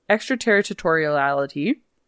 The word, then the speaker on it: extraterritoriality: extraterritoriality, Mm, yeah, try again. – no, I said that right.